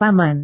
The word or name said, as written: paman